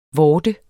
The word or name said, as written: vorde